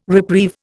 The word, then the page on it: reprieve: Indian